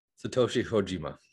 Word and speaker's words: Satoshi Kojima: Satoshi Kojima.